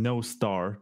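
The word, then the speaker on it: Nostr: It's popular among Noster users. I mean, No Star, that's the way it's actually pronounced.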